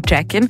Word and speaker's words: Joaquin: Hot Die queere Vloggerin Rosemary Jackin, so geschrieben wie der Vorname des bekannten Schauspielers Joaquin Phoenix, aber ausgesprochen Jackin, die ist aufgewachsen in Washington D.C.